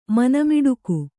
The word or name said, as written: ಮನಮಿಡುಕು